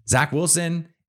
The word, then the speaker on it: Zach Wilson: Zach Wilson, if he turns out, did I say it right the first time? Yes, I did if they if he turns out to to take that year two leap, I think this becomes a much closer game.